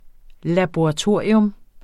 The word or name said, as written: LABORATORIE